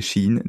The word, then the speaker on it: Shein: Même si euh, je trouve que le modèle Shine en lui-même euh, devrait euh, être un peu plus régulé. – Hmm. J'ai j'ai checké, donc c'est Shein, la prononciation.